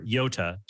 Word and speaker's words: iota: Iota, or iota, to pronounce it more correctly, is the Greek letter I in the Greek alphabet.